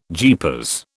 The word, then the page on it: jeepers: British English